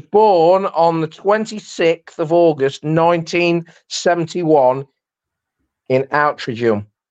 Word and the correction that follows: Altrincham: You mean Altrincham? – Yeah, sorry, I said it wrong.